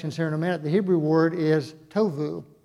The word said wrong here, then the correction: tohu: tohu. It's how you say it, tohu.